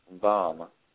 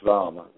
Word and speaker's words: Vam: And the chant for that one is Bam. It's spelled B-A-M. And B as in boy? – Hold up. It's Vam. V-A-M.